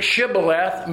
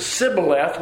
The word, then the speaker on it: shibboleth: If he said no, they would say the word shibboleth, meaning stream, flood, ear of corn. The men of Ephraim could not say the word correctly. They pronounced the word sibboleth without the H, meaning ear of grain or burden.